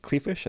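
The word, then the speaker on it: Kleefisch: Rebecca Clefish, I think I'm saying that right. – You are. As a former resident of Wisconsin.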